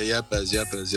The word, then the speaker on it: Yepes: Yeah, Yepes.